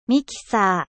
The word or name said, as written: mixeur